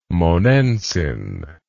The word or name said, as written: monensin